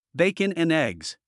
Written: bacon and eggs

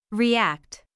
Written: react

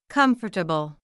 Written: comfortable

